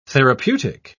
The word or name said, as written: therapeutic